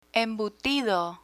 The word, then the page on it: embutido: Spanish